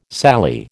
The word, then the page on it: Sally: American English